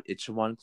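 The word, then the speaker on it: Itaewon: It's called Ichiwan Class.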